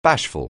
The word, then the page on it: bashful: anglais